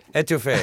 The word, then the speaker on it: étouffée: Étouffée.